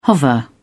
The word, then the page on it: hover: British English